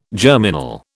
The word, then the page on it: germinal: British English